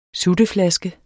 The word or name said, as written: sutteflaske